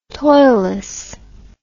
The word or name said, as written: toilless